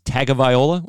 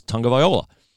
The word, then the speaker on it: Tagovailoa: Remember when it was Tagovailoa? Everybody thought it was Tagovailoa. Well, we later learned it was Tagovailoa.